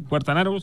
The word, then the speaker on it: Quartararo: Bueno, pues hay que decir que se corrió en Portugal y ganó Quartararo, creo que se dice así. – Quartararo, sí.